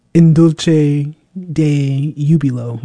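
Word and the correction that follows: In Dulci Jubilo: Well, let me make sure I got all of that right. Oh, gosh, the pronunciations. In Dulce Jubilo. There we go.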